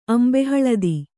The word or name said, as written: ಅಂಬೆಹಳದಿ